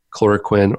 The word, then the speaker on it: chloroquine: but chloroquine. – Yeah, hydroxychloroquine. Mm-hmm. And chloroquine. – Okay. – Yeah, chloroquine.